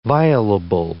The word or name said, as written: violable